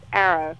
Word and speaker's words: ARO: It's actually pronounced Arrow.